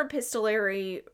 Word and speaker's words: epistolary: Epis- epistolary.